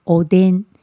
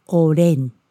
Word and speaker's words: おでん: 像比如说「おでん」，台湾人就会把它叫做「おれん」、「おれん」。